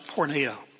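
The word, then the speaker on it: porneia (πορνεία): The Greek word is pronounced porneia.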